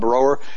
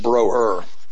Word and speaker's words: Broer: Like my name with an umlaut over my name's actually supposed to have an umlaut. Brower. It's supposed to be brr is what it's supposed to be, and we pronounce it broer.